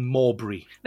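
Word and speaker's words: Malbry: Well, those books, well, let's talk about those books then, because they're set in the fictional town of, I think I pronounced this right, by saying Morbury. – That's right, yes. – It's spelled M-A-L.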